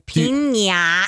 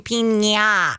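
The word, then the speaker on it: piña: Pina colada is Americanized, but pina. It's got the squiggly thing over the N. You have to say pina. – It is not pina colada.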